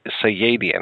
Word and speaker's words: Sayadian: I wanted to ask you, how did you and Stephen, how do you pronounce Stephen's last name? Is it Sayadian? Sayadian, okay.